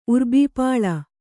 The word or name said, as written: ಉರ್ಬೀಪಾಳ